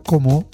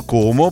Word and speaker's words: Como: Con la O stretta, perché lì, sai, parlano così, sono di Como. – Senza l'accento finale, semplicemente sarebbe Como. – Esatto, Como, poi c'è Burmio, poi c'è Sundrio.